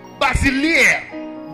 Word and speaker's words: basileia: Basileia.